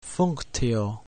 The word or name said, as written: functio